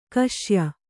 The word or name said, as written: ಕಷಾಯ